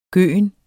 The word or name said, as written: gøen